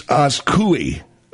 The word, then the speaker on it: Azcuy: Anyway, few people will take a billionaire head-on, but that's just what Masa Saidi Akukulu Walabatara, I don't know, her name is, like, spelled A-Z-C-U-Y. – A-Z. Spell it again. – A-Z. – A-Z. – C-U. – C-U. – Y. – Y. Asku. – Yeah. – Asku. – Yeah. – It's Askui. Askui.